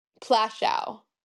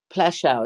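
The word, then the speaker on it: Płaszów: It's Plaschow. – Plaschow, is that how you yes.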